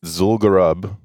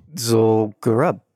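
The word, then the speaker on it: Zul'Gurub: Zul'Garub. – Zul'Garub.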